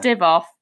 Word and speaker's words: Divoff: Andrew Dolph is the, I think I'm saying that right. – No. – Andrew Dolph. – Oh yeah, do you remember? Because we were trying to pronounce his name and I was like, oh, yeah. Divoff. – But thanks. Yeah, that's right.